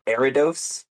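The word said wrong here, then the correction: Ariados: evolves from Eriados. – Eriados? That makes way more sense because there's an I in it.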